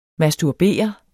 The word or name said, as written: masturbere